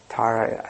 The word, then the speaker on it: Tara: Tara with a long A on the end is like, you know, the goddess Tara, I think, means like savioress.